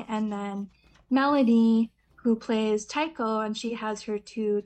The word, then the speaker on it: taiko: Am I saying it right? Taiko? – Yeah.